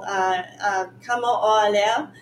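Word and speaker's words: Kamo'oalewa: It's called Kamo'olewa. That's how you say it. It's Hawaiian, or Polynesian.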